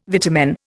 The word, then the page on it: vitamin: Indian